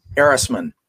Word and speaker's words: Erisman: Erisman.